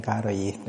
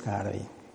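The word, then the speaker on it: Károli: Úgyhogy ez a helyzet, csak mondom, az én szerintem a kiejtés az mindenképpen Károlyi, mert hogy akárhogy írjuk. Tehát nem fogunk ebből hitvitát csinálni, hogy most ott van ez az Y, vagy nincs ott van az Y, de a kiejtés az Károlyi.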